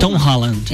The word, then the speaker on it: Tom Holland: O Tom Holland, como é que fala? Tom Holland.